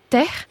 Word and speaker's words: teh: Donc le mot du jour, c'est « terre ». On l'écrit T-E-H, mais on l'entend souvent prononcer « terre ».